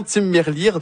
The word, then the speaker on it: Tim Merlier: Oui, c'est le Belge, donc un autre nom en flamand, Tim Merlier. J'ai confirmé sa prononciation, Tim Merlier,